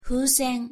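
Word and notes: fusen: Japanese

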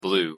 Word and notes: blue: English

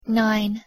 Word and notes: nine: English

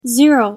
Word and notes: zero: English

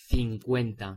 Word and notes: cincuenta: Spanish

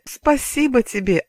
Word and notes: спасибо тебе: Russian

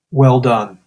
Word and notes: well done: English

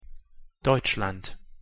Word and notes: deutschland: German

